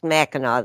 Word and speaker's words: Mackinac: She's talking about Mackinac and Mackinac Island. They're both pronounced Mackinac. They're just spelled different.